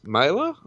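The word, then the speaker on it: Mahler: He was played by Bruce Mailer. I hope I'm saying his name right. – Yeah.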